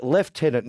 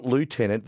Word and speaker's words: Lieutenant: Adam, you are a great presenter, but FFS, it's Lieutenant, not Lieutenant. That's Trump's way of pronouncing it, says Sando.